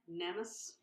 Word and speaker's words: Nemes: Nemes. – Nemes. N-E-M-E-S.